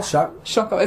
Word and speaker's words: Chocolat: Is it called Chocolat? It's spelled like chocolate, but it's pronounced the French way because it's set in France.